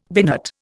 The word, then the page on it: binate: Indian